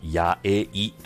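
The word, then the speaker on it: 8A: はい、数字の 8 にアルファベットの A でやえいと読みます。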